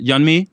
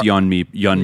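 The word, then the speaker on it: Yeonmi: Yon is, have I pronounced? I'm uh, sorry, I pronounced it. – Yanmi. Yep. – Uh, I think it's Yonmi Mi yeah.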